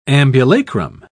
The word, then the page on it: ambulacrum: British English